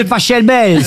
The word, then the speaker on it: Pachelbel: Ça s'écrit P-A-C-H-E-L-B-E-L. – Mais il adorait l'Italie. – Alors, par contre, Laurent, s'il est Allemand. – Cannelloni, c'est Pachelbel. – S'il est allemand, ça se dit Pachelbel.